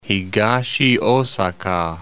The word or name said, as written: Higashiasaka